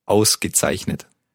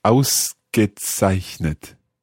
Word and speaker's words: Ausgezeichnet: Ausgezeichnet. – Let's take it syllable by syllable. The first word, the first syllable? – Aus. – Aus. – Gezeichnet. – Zeichnet. Ausgezeichnet. – Yeah, very good.